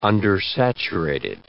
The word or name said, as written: undersaturated